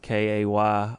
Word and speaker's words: Cairo: Yeah, the way we pronounce it is it's spelled Kayrow, not Cairo, Egypt.